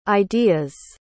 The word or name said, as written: ideas